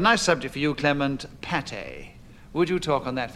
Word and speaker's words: pâté: Pronounced differently, like pâté, which often has a circumflex over the A and an accent aigu alongside the E, it is a mass of meat often held together with egg, flavored with onion, and as often as not, herbaceously...